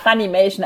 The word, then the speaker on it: Funimation: also es ist wahrscheinlich eher Fun und Animation, das glaube ich auch. – Ja, Funimation.